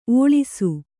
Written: ಓಳಿಸು

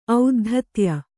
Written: ಔದ್ಧತ್ಯ

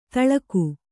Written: ಥಳಕು

